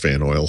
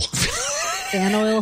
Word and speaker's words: Faneuil: Fan oil. How do you pronounce that? Faneuil Hall? – Yep. Fan oil. – Fan oil hall.